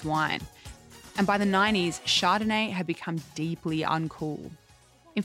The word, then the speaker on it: Chardonnay: Mum, it's French. The H is silent. Back me up here, Kylie. – Yeah, she's right, Mrs. Deer. I've been to Paris and the H is silent. It's Chardonnay.